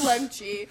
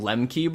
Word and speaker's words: Lemche: You're the only person who gets Chris Lemche. That's how you pronounce his name, right? – I looked it up, that's what it said. Yeah, that's what it said online. Yeah. I was calling him Lemke before, and online it said it's a ch, and I was like, all right.